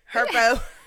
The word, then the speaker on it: Herbo: What I can't get with is this whole Herb and Ari situation. Well, how you pronounce his name? Herba? – You call him Herpy. – Herpy. Herpes. What you? – Herbo. G Herbo.